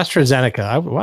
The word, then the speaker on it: AstraZeneca: Yeah, AstraZeneca. I can't believe I pronounced that right.